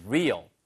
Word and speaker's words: real: How do you know if it is real? 자, 그래서 바로 R-E-A-L 이거 알아두세요. 발음이 real 하면은 이거는 진짜의란 뜻이고요. 그 다음에 R-E-A-R 후방의란 뜻을 쓸 때는 rear. 걔는 rear, 얘는 real. 을 발음이 하는 거죠.